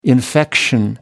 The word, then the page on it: infection: American English